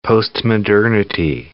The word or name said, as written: postmodernity